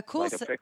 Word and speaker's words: coolicle: I guess I've probably seen this before, but so this is called a cool sickle. So basically, a coolicle. – Oh, okay. – Sorry, I kind of mispronounced that. A coolicle.